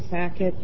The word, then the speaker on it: Sackett: My name is Amy Sackett, and it's spelled A-M-Y. S like it's Sam, A-C-K-E-T-T.